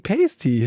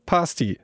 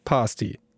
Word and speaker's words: pasty: Fast jeder, der das liest, sagt natürlich, Tasty Pasty, ja, lustig. Und dann wird er erstmal ordentlich auf die korrekte Aussprache verwiesen. Denn erst, wenn du richtig Pasty sagen kannst, Pasty, Pasty, dann kannst du Daniel und Paul auch fragen, was das überhaupt ist.